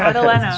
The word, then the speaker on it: Madalena: Did I get that right? – Madalena. – Yeah, okay. – Madalena. – Okay. Short E.